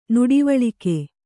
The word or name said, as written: ನುಡಿವಳಿಕೆ